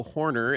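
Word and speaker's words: Hoerner: His name is Nico Horner, and different spelling, same pronunciation.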